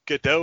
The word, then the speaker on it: Gadot: We mentioned before, I think Gal Gadot or Gal Gadot. I don't know how to pronounce it. – I think it's Gadot. I think you pronounce the T.